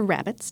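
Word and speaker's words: rarebit: It's also a common ingredient in deviled eggs, Caesar salads, and Welsh rarebits. – Or rabbits, depending on how you want to pronounce it.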